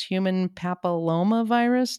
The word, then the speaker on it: papillomavirus: That's human papillomavirus.